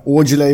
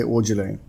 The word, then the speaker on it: Aujalay: Aujalay uttalas. Det är brittiskt uttal på namnet, så man säger Aujalay.